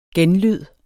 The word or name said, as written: genlyde